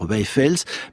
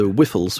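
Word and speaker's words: Wijffels: Le nom se prononce à la néerlandaise ou à la flamande par Wichfels, mais c'est un nom qui se prête à des prononciations très différentes d'un pays à l'autre. – Quand vous êtes docteur de Cambridge, ce que vous êtes, comment dit-on ? – Oh, les Anglais auront une prononciation du type de Wichfels, quelque chose comme ça.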